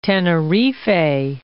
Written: Tenerife

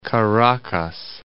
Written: Caracas

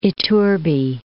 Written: Iturbi

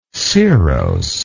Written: cirrosely

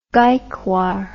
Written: gaekwar

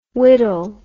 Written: widdle

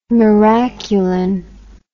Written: miraculin

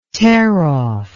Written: tearoff

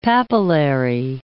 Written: papillary

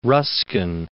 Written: Ruskin

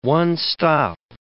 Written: one-stop

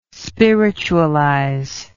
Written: spiritualize

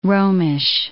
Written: Romish

